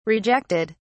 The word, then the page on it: rejected: British English